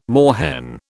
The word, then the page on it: moorhen: British English